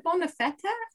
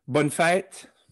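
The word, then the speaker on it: bonne fête: Is it Bonafetta or Bonafette? – Bonfait. It's two syllables.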